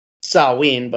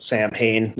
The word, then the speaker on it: Samhain: going from the Misfits to Samhain, which is, you know, Samhain, but, you know, he pronounces it Samhain like a goofball, so we'll just go with that.